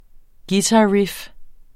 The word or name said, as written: guitarriff